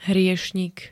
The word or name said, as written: hriešnik